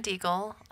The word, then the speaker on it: Diegel: My name is Aaron Deagle. Last name is spelled D-I-E-G-E-L.